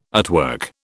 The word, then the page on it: artwork: British English